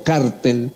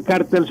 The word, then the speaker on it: cártel: Si las palabras cartel o cártel con acento en la A tienen el mismo significado.